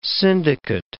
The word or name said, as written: syndicate